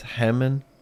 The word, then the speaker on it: Hemmann: Did you know his last name is, it's spelled like He-Man, but it's Hemman? – I did know that. – H-E-M-M-A-N-N, He-Man.